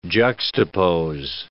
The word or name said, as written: juxtapose